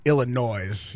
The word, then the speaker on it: Illinois: I mean, I get like if you're like Illinois, you know, that's the S is silent.